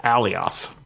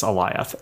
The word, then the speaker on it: Eliath: Yeah, I always want to pronounce it Alioth, but it's Eliath.